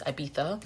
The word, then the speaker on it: Ibiza: You're not supposed to pronounce the Z. It's Ibiza.